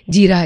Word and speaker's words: जीरा: और जीरा भी बुलाते हैं इसको, लेकिन I think the real Indian pronunciation would be जीरा.